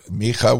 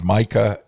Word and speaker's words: Micha: And with the help of his brother, Micha, we would pronounce it Micah in English. In Hebrew, it's Micha.